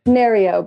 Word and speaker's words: Nereo: Nereo, but...